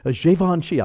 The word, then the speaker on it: Givenchy: Givenchy.